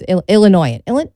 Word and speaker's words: Illinoisan: You know, with the S, it's a silent S. It's so hard. I was thinking to myself, how do we call ourselves? Do we call ourselves Illinoisan? No, it's Illinoisan.